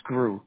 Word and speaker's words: Groulx: Grou, G-R-O-U-L-X. – You said G-R-O-U-L-X? – Yep, that's it. It's pronounced Grou. It's French.